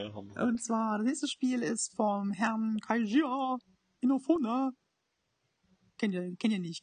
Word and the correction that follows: Keiichi Inafune: Ja, aber ich kann es auch nochmal richtig aussprechen. Äh, von Keiichi Inafune, äh, so der ist mit dafür verantwortlich.